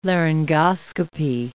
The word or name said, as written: laryngoscopy